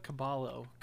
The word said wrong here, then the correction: Cabello: You mean Camila Cabello?